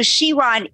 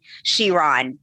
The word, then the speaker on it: Chiron: And I want to say that, so Chiron is a correct pronunciation for the Bugatti Chiron, the most, a most beautiful car that Bugatti makes is the Chiron, and pronounced exactly in the way that you're saying it. When you look at the minor planet slash comet, because it does have two designations in astronomy, it's pronounced Chiron, as if the C CH was a K.